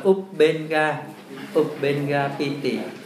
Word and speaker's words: Ubenga Piti: Tiếp Ubenga Piti, chữ này đọc là Ubenga Piti,